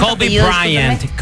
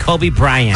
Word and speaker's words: Kobe Bryant: Kobe Bryant, Kobe Bryant.